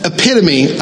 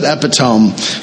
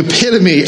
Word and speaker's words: epitome: The epitome, I almost said epitome because I'm so used to saying it wrong. The epitome of the American dream is the individual that pulls himself up by his bootstraps.